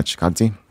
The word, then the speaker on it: Chikadze: Yeah, Chikadze.